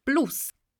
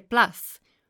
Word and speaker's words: plus: A volte per istinto, perché la sentiamo sempre pronunciata eh, con l'accento inglese, ci viene da dire plus, ma in realtà l'originale, la pronuncia che noi usiamo eh, in modo corretto è plus e quindi torniamo al nostro corso, durante il corso Plus, in una delle ultime lezioni, abbiamo parlato della bellezza dell'italiano.